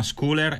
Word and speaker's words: Scooler: Un piccolo dettaglio sul nome: si chiama Scooler, e come se lo leggete ovviamente è un po' diverso da come lo pronunciamo. Le startup tipicamente hanno questa peculiarità, devono cercare dei domini che non esistono, devono cercare dei nomi originali. In questo caso si scrive School e poi c'è la R che è pronunciata.